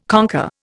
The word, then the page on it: conquer: Indian